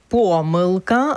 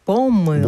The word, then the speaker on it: помилка: Наше сьогоднішнє слово дня – помилка або ж помилка. – Бонд. – Ні, два Бонд. Наголоси допустимі. Це слово, яке має два допустимі наголоси.